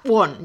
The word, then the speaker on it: Won: Lo primero explicaros que es Lidia Won de inglés. O sea, Won, ya lo he dicho bien, Lidia Won.